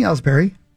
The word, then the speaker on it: Ellsbury: By releasing Ellsbury, that's how you say it, okay? – Yep.